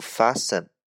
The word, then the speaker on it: fasten: 第一个，它和快 fast 没有关系；第二个，它的发音读 fasten，不读 fasten 啊，fasten，它的意思叫拴牢。